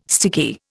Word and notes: sticky: Indian

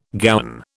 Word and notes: gowan: British English

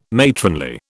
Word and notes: matronly: British English

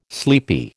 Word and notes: sleepy: American English

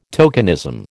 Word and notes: tokenism: American English

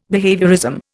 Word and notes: behaviourism: Indian

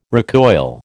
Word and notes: recoil: American English

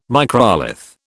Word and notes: microlith: British English

